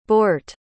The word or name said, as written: boort